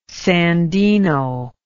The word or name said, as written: sandino